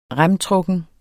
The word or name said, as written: remtrukken